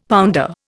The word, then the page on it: pounder: Indian